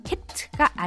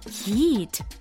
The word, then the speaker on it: heat: heat가 아니라 heat 길게 발음해야 됩니다.